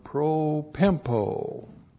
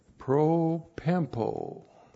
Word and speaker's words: propempo: And then we have the verb propempo. Propempo. P-R-O-P-E-M-P, long O. Propempo.